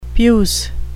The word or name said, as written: puce